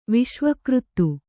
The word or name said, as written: ವಿಶ್ವಕೃತ್ತು